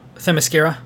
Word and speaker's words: Themyscira: Um what's interesting is that how do you pronounce it? The, their island? – Themyscira? – Yeah. That Themyscira is um it's you know isolated.